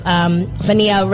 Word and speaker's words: Fania: The Fania, am I saying that right? – Mm-hmm. Fania.